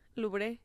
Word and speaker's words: Louvre: Que Beyoncé le encantaría tener un rato de pasión en el museo de el de París, ¿cómo se pronuncia? Louvre. – Louvre.